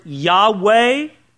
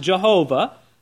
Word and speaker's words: Yahweh: It's in those small capital letters, which means it's the Hebrew word Yahweh sometimes erroneously pronounced Jehovah, but it's the Hebrew word Yahweh, the sacred name of God, the tetragrammaton.